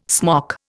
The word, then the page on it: smock: Indian